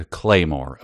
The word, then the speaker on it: claymore: I mispronounced it. A claymore, of course, is the. – What did you say? – I think I said Claiborne, which is either makeup or a model or something.